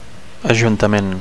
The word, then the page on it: ajuntament: Catalan